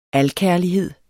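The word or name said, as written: ALKÆRLIGHED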